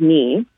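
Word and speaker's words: me (米): So they would use an emoji picture of a rice bowl, which is pronounced me, and then a bunny, which is pronounced too.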